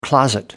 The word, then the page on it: closet: American English